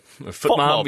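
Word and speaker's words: FotMob: So FootMob, is that how you say it? – Yeah. FootMob, yeah, whatever it is, yeah.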